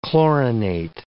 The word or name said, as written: chlorinate